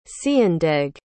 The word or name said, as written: ceindeg